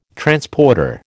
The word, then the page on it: Transporter: American English